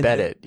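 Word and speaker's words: Beddit: Didn't they acquire Breadit or something? – I think you added an extra syllable in there, but again, Bedit, yeah.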